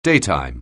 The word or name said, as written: daytime